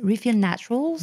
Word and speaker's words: Refill Naturals: que je vais te citer, c'est Refill Nature j'arrive jamais à prononcer, Refill Naturals en anglais, mais c'est une marque française.